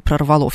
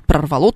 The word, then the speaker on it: прорвало: глаголы в прошедшем времени в среднего рода, вот это вот прорвало, а не прорвало. Все же говорят прорвало трубу, но это неправильно, правильно говорить прорвало.